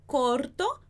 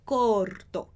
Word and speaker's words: corto: E o último, corto, porque tem um R antes de uma consoante, antes da letra T. Corto.